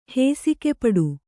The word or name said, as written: ಹಸಿಗೆಪಟ್ಟಿ